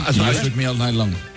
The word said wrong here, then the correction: Shook Me All Night Long: Ah, sí. Lo has dicho en francés. – O sea, has cambiado cuatro palabras de sitio. – Has engolado tanto que se te ha pasado de idioma. – Qué grande, tío. Qué grande. – Me cuesta el Shook Me All Night Long. – Ahí mucho mejor.